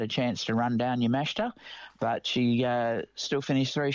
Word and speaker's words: Yamashita: Yamashita, as I said it properly, who played so well over here in Australia, and she won the British Open.